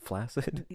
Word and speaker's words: flaccid: Yes, but it's pronounced flaccid. It actually has. – No, you're saying, okay, so you're saying flaxseed, like the little things you put in your smoothies? – No. – That give you like the extra protein? – It is the adjective. It is flaccid. In addition to the soft C, it actually has flaccid. So you're saying flaccid. – It has a hard C as well.